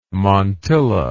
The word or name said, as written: montilla